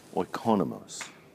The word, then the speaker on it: oikonomos: Oikonos, how do you say that? Oikonomos.